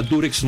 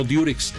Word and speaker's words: Durex: Así se llama Durex, no Diurex. Lo que pasa es que nosotros pronunciamos, al decir Diurex, pronunciamos la U como se pronuncia en inglés, U, okay, ¿no?